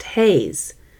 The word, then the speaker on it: HAES: If you aren't familiar with this phrase, it comes out of the body positivity movement and is also referred to by its acronym H A E S, pronounced HAZE.